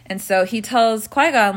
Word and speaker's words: Qui-Gon: So, Qui-Gon, the movie starts off with, am I saying his name right? – Yeah, Qui-Gon.